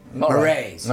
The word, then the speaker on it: Moraes: Don Moraes. How do you say his name? – Moraes. – Moraes. Because I never knew that, you see. I didn't know how to say it.